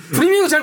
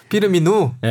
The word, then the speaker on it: 피르미누: 이제 살라 선수를 두면서 피르미누를 뒤로 뺐는데 잠깐만 누구? 피르미누. 아유 발음이 꼬였네. – 프리미누 잘 모르네. 아는 척하다 다쳤다. 피르미누.